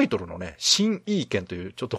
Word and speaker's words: 形意拳: 形に意味のある拳と書いて、新意見と。 – はい、ふうに読むんですが、これは日本語タイトルなんですね。